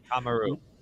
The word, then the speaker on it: Shikamaru: Shikamaru. When he's like Shikamaru. Shikamaru. – Oh, sorry, I was putting the accent on the wrong letter.